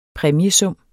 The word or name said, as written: præmiesum